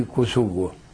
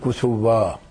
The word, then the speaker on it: كوسوفا: وأما اسمي في الهوية الشخصية وفي دفتر العائلة وجواز السفر وغير ذلك من الأوراق الثبوتية قدري ابن صوقل الأرنؤوط، ولادتي في كوسوفو. هل نحن في الألبانيين نقول كوسوفا، الصربيون يقولون كوسوفو.